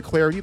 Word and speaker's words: Clardy: I'm Troy Clarity. Follow me on Twitter at Troy Clarity. The last name is spelled C-L-A-R-D-Y.